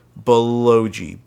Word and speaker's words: Baloji: I believe it's pronounced Baloji. B-A-L-O. J.I.